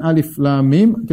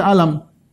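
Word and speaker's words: Alif Lam Mim: Ketika Allah mengatakan alif, lam, mim, kita bacanya satu-satu. Alif, lam, mim. Bukan kita baca alam. Ini bacanya alif, lam, mim. Bukan alam. Bukan alim, bukan alam, ya.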